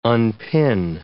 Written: unpin

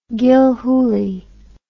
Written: Gilhooley

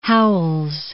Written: Howells

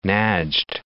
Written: Najd

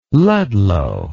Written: Ludlow

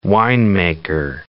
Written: winemaker

